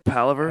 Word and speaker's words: palaver: saying that right? Palaver? – It's palaver. – Palaver.